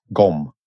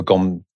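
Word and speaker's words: gqom: In Durban, in South Africa, you should check out GOM. G-Q-O-M, I think it's spelt. And put a GOM track on the album.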